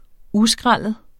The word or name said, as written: uskrællet